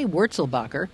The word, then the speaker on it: Wurzelbacher: McCain said Obama's plans would deny Wurzelbacher, the correct pronunciation, his goal of buying his own plumbing company.